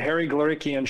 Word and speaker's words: Harry Glorikian: It's the Harry Glorikian Show.